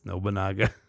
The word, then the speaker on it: Nobunaga: And I'm like, no, it's Nobunga. And I go back and look and I'm like, holy shit, it's Nobunaga. So probably about a year and a half ago, I realized that I've been saying that wrong for my entire life.